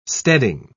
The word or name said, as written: steading